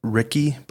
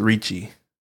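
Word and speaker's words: Ricci: It's R-I-C-C-I. I don't know if it's Ritchie or Ricky, but... – What a name. – Maybe it's Ritchie. – Ritchie? – Yeah, I think that's how they pronounced it, actually.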